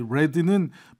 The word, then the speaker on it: read: 아, 이 red는 불규칙 동사 변화라 read, red, red 이렇게 된 거예요.